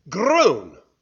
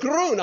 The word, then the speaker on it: grün: When we were in Germany or in Austria and we were learning the German language, I struggled to say the word for English green. Now, it's spelled G-R-U-N, grün. And every time I say it, the people would smile and they'd say, "It's not grün, it's grün." I said, "That's what I said."